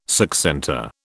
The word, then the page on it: succentor: British English